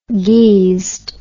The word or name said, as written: geezed